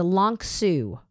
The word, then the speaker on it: LONXU: It is the Long Su. That's spelled L-O-N-X-U. Okay, Long Su.